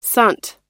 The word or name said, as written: sant